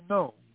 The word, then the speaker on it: gnomes: We are the gnomes, all gathered around you in your home, and speaking through our telepathic phone. That is spelled gnomes, G-N-O-M-E-S, a silent G,